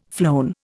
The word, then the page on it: flown: Indian